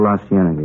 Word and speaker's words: La Cienega: Over on, that street out that way, La Cienega. Is that how you say it? – Well, La Cienega, yeah.